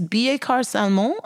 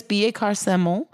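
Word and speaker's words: Billecart-Salmon: I don't actually know how to pronounce it. It's Billet-Car Salmon, I think is how you say it.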